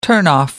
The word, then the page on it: turnoff: British English